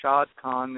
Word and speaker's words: Shahid Khan: And, of course, the owner of the Jacksonville Jaguars is Shahid Khan, spelled S-H-A-H-I-D, but pronounced Shahid Khan.